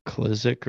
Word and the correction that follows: McKissick: Oh, you're talking about McKissick. – Yeah, McKissick.